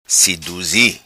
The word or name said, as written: Siduzi